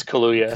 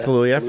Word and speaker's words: Kaluuya: I think it's Kaluuya. – Kaluuya, for Get Out.